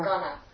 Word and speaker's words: gana: gana. – It? – Gana. – Gana.